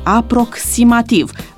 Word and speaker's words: aproximativ: În schimb, aproximativ se pronunță cu ks, aproximativ.